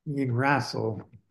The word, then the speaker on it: Yggdrasil: So the Norse had it's spelled YGGRASIL. Correct me if we're wrong, but we'll pronounce it Yggdrasil.